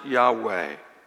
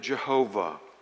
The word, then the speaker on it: Yahweh: We pronounce it Yahweh. A few years back, they pronounced it Jehovah.